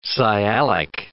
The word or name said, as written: sialic